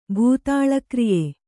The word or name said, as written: ಭೂತಾಳಕ್ರಿಯೆ